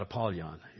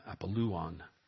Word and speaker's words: Apollyon: I know you've probably heard it pronounced Abaddon. That's not a correct pronunciation in the Greek language or in Hebrew or in Apollyon. It's not Apollyon. It's Apolluon in this case.